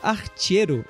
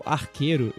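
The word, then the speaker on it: Archero: E para finalizar, eu também joguei um jogo para é, dispositivos móveis, como eu, eu, mencionei, chamado Archeiro, ou Arqueiro, eu acho, mas tem um. É A-R-C-H, né? Então pode ser Archeiro ou Arqueiro, se você usar aí outra pronúncia, enfim.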